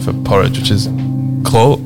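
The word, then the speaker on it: grød: You stick your tongue out. Exactly. That's what you do. – And that's, what I thought was fascinating. – Klød.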